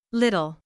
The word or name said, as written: little